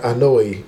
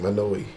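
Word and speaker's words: Anoa'i: Yeah, Anoue, because I think that's how you pronounce that family name, Anoue.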